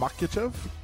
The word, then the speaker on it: Makhachev: Islam Makhachev.